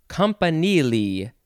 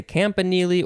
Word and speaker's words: campanile: Okay, next we have Campanile. Compa- you could say Campanile or Campanile. You could also say Campanile. Campanile. It is spelled C A M P A N I L E.